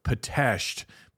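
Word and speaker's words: Pitești: And it looks like, if you want to look it up, it's spelled P-I-T-E-S-T-I, but it's pronounced Pitesti, but it looks like Pitesti.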